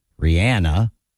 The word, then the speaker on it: Rihanna: Rihanna, that's how she pronounces it.